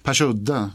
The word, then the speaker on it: Persudde: Så har ni tid, eller ta er tid, och upp till Persudde. Eller Persudde. Man ska uttala det så.